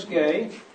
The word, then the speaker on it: Gay: My name is Amos Gay. For the record, my surname is spelled G-A-Y, not G-U-A-Y, but just for the record.